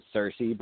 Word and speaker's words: Saoirse: It's apparently, I think it's Saoirse, but it's spelled S-A-O-I-R-S-E.